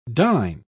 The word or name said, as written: dine